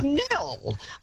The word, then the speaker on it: Nhill: But look, Kate, also, our town, Nil, N-H-I-L-L, and I get so much, I've just been into Melbourne and, you know, checked into hotels, they cannot pronounce the silent. They say N-Hill. Excuse me. It is Nil.